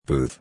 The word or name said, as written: booth